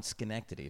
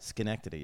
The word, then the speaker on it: Schenectady: I was actually born in Schenectady, if you want to have a fucking pronunciation problem. – What? – Schenectady, yeah. – Schenectady? – Mm-hmm.